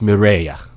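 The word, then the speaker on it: Mireille: You know, like, I don't want to get to know Kirika or Mireru, or Mireyu. – Right. – Because these two are like, okay, somebody please kill me for my French. But anyway. – I think it's pronounced Mireya.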